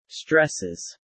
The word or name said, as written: stresses